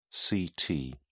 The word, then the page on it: ct: British English